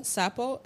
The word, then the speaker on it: Sapo: Sapo. S-A-P-O.